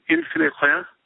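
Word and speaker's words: infini rien: Well, the most famous passage of the Pensée is this Pascal's wager, though he himself names it, and forgive me, my French pronunciation is not good, infinite rien, is it? – Yes, infinite nothing. – Infinity and nothing, that's right.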